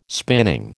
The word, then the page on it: spinning: American English